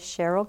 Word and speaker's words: Sherryl: My name is Sherryl Guillory. Sherryl is spelled S-H-E-R-R-Y-L. Guillory is G-U-I-L-L-O-R-Y.